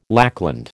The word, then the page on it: lackland: American English